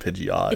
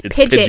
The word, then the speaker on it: Pidgeot: Technically, the correct pronunciation of Pidgeot is not Pidgeot. – It's Pidget. Pidget. Oh, we said Pidget.